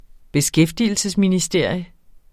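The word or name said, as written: beskæftigelsesministerie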